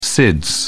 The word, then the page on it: SIDS: British English